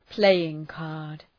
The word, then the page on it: playing card: ‘pleııŋ,kɑ:rd